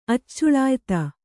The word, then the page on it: ಅಚ್ಚುಳಾಯಿತ: accuḷāyita